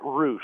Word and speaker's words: Roush: It's Matt Roush. – Roush. – Yes. Rhymes with Nike swoosh.